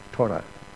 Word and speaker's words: Torah: Torah, as it's pronounced in Hebrew.